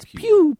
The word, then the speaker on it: Pugh: The G is silent. – Yeah. – It's pew, pew, pew, pew, pew.